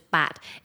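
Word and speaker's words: bat (八): When you say 8 in Cantonese, which is bat, it sounds like the word fat, which means fortune.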